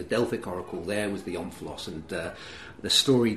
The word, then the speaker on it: omphalos: Omphalos. I don't know how to pronounce it. How would you pronounce it? – I pronounce it omphalos.